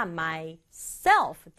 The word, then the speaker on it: myself: 발음할 때는 myself가 아니라 myself 뒷부분에 강조 줘요.